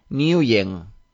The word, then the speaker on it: Nguyen: Se llama, se apellida Nguyen. – Sí, y además lo pronuncian y lo dicen sin la N, o sea que yo creo que algunos lo hicimos mal.